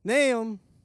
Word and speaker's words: Naaman: So Naaman, I think that's how you pronounce it, N-A-A-M-A-N, Naaman goes over and goes and takes the dip 7 times.